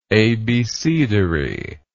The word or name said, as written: abecedary